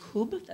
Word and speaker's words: kubb: And they play a Swedish game called Kub, that's how it's pronounced, K-U-B-B with a normal out on the U.